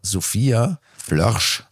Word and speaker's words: Flörsch: Und was ich sagen wollte, und das ist ein Thema, das hat mich ein bisschen beschäftigt, wir haben Sophia Flörsch kennengelernt.